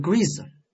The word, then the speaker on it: grease: grease 名词动物油脂、润滑油，及物动词读成 grease，意思是用油脂涂给什么什么加润滑油。